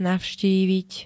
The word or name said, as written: navštíviť